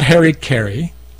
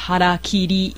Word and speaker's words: harakiri: And it's not Harry Carey. – It's harakiri. – Harakiri.